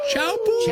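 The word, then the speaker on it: Teahupo'o: I believe it's pronounced Chaupu.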